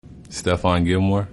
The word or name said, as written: Stephon Gilmore